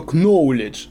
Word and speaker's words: Knowledge: Ну, знаешь, кто похуже бы сказал, что Knowledge. Я уже знаю, что там Кей не читается.